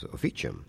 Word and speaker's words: officium: Well, actually, it comes from a Latin word pronounced the church way. It was officium.